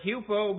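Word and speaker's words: hupo (ὑπό): It's from two words in the Greek, from the word hupo, meaning under, and the verb akouo, meaning to hear.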